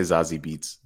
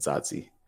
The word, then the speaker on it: Zazie: A lot of people say Zazie Beats, but that's not how you say it. Zazie.